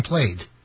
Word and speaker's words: Plaid: It's really called Plaid, like you've been Plaid. – Yeah, cause I'm going by how it's spelled, like the vowels and stuff. You got the A and the I, but it's just pronounced Plaid.